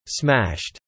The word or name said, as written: smashed